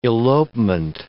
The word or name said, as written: elopement